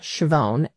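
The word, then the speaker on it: Schiavone: And if you search under faculty and staff, my name is Ann Chavone, A-N-N, and the last name is spelled S-C-H-I-A-V as in Victor, O-N-E.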